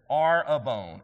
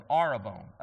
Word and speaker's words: arrabon: In the Greek, it's spelled A-R-R-A-B-O-N, arabon, arabon.